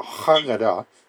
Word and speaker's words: Haggadah: Well, there is a Jewish body of writings that are called the Haggadah. Or I suppose if you roll the H, it's a Haggadah, which means the telling. Haggadah is spelled H-A-G-G-A-D-A-H, if you're keeping track, Haggadah.